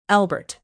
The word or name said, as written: albert